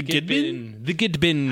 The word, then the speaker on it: Gidbinn: The Gidbin. How do you spell that? – Gidbinn. – Oh, okay. Like it sounds. Okay. – Yeah. It has two N.